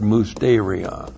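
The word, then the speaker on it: musterion (μυστήριον): And that is specifically, there's a word that describes the God-breathed scriptures for this dispensation, and that's the Greek word musterion. Looks like that in the Greek, M-U-S-T, long E-R-I-O-N.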